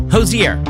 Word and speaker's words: Hozier: Hozier or Hozier. I mean, how you say it? Well, just so happens, we found out it's Hozier.